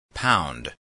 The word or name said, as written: pound